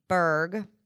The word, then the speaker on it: Burge: And finally, Emojipedia, which was founded by a man named Jeremy Berg. Hope I'm pronouncing his name, last name. – It sounds right. – B-U-R-G-E.